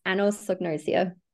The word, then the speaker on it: anosognosia: It's anosognosia.